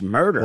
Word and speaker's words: Merder: His last name was Murder, M-E-R-D-E-R, but pronounced Murder.